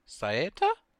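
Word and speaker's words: Saetta: It's a TV short done by Studio Saeta? Is that how you say it? – Yes.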